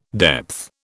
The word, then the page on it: depth: British English